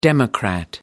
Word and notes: democrat: American English

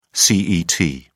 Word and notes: cet: British English

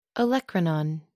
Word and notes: olecranon: American English

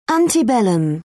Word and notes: antebellum: British English

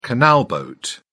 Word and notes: canal boat: British English

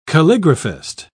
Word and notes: calligraphist: British English

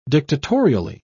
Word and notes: dictatorially: British English

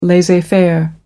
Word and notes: laissez-faire: American English

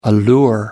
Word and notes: allure: American English

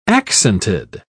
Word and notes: accented: British English

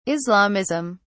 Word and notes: islamism: British English

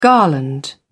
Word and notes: garland: British English

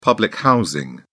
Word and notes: public housing: British English

